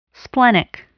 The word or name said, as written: splenic